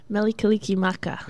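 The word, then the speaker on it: Mele Kalikimaka: But the Hula Monsters before that with, how do you say it? – Melikalikimaka. – Yeah, very good.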